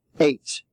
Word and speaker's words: eight: With the other form of long A is E-I-G-H. That is the four letters themselves. E-I-G-H stands for the A sound, as in way. Eight. E-I-G-H-T.